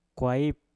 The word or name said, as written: kwaip